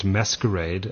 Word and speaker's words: MSQRD: MSQRD, and it's pronounced Masquerade, and it's quite easy to see why Facebook bought it, because it's a competitor to Snapchat.